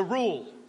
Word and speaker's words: Rule: And but she had me listening to Rule, which is pronounced like the word drool, which is quite fitting, I think, who, incidentally, Rule is free to spell his name with phonetic imprecision.